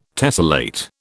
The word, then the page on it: tessellate: British English